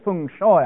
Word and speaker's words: feng shui: or Feng Shui, I think you're supposed to pronounce it.